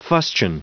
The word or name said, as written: fustian